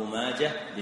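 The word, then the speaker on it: ماجه: يا صاحي نطق ماجة بالها بلا لجاجة، يا صاحي نطق ماجة بالها بلا لجاجة، يعني كلمة ماجة تنطق بالها ولا يقال ماجة.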